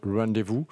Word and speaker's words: Rendez-vous: Alors c'est Rendez-vous sans tiret, donc je pense qu'il faut peut-être le prononcer à l'anglaise, Rendez-vous, d'autant plus que tous les titres du disque sont en anglais.